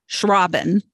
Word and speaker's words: Schrauben: And today I have with me Cindy Schrauben. And she had a really great way of telling me how to say her name. It's like shushing the robin, which I guess since she is a children's author, that is a really good explanation for children.